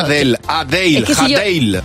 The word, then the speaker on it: Adele: Yo, claro, diría Adele. – Adele.